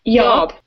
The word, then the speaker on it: YORP: By the way, how do you pronounce it? – YORP. – With the Texas accent, like y'all, YORP? – Yeah, I guess it's kind of like y'all, but with a P at the end instead of an L.